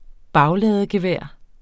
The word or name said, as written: bagladegevær